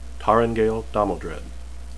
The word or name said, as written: taringail